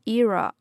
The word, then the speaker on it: era: Recession era，这里的这个 era 也可以读作 era， OK。